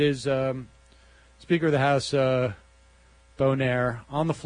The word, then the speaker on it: Boehner: I did notice Nancy Pelosi still does not know how to pronounce his name. It's difficult because it's spelled differently, but he is, I think, French-Canadian descent. It is Boehner.